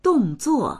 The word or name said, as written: dong4zuo4